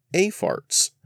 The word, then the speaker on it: AFRTS: which has the acronym AFRTS, and according to several different sources is affectionately pronounced AFARTS.